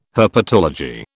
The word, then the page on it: herpetology: British English